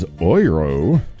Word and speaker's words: Euro: Before that was Oiro, O-I-R-O, which is the phonetic English spelling of how you say the euro in German.